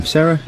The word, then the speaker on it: Cera: And the kid, Michael, is it pronounced Sarah? Michael, C-E-R-A. – Yeah, Sarah.